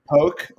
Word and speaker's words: poke: Well, you nailed the pronunciation of poke. Usually we get poke or pokey. Not quite the same, but yeah. If you ever poke started in Hawaii, and that's how they pronounce it there.